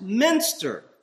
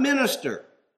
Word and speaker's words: Minster: And it's, by the way, pronounced Minster, from Munster, not minister. If you say Westminster, everybody should be looking at you going, what? A minister who lives out west somewhere? No. Minster, the church.